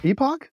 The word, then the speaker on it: epoch: For our third story, humanity could soon enter a new geological epoch, or epoch, I guess we're pronouncing it today, and it all starts in Canada.